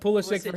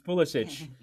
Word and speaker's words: Pulisic: It's not Pulisic for him. It's Pulisic.